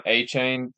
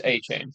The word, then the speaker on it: Achane: His name is pronounced Achan. – I don't care. It's Achane. – They said Achan. That's what they say. – If I'm going to pronounce. I will pronounce it wrong. I don't care how stupid it sounds. I'm saying A-Chain.